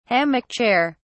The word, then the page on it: hammock chair: British English